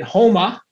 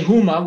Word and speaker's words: Houma: And I dropped it off in Houma. You can't say Houma, which is how it's spelled. You got to say Houma.